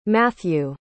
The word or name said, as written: matthew